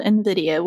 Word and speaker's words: Nvidia: and also a company called Nvidia, which is basically a company that makes graphic processing units. It's not spelt how it sounds, so it's spelt N-V-I-D-A if you wanted to Google it.